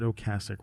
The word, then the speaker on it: Ocasek: Some people say Ocasek uh, is how I said it, but he pronounced it Ocasek.